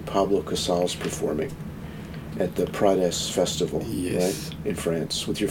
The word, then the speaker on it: Prades: It's pronounced Prades.